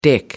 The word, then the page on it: dick: British English